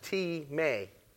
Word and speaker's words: timē: Now the word honor comes from the Greek word timē, It's spelled T-I-M-E, but it's pronounced T-May.